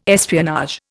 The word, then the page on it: espionage: Indian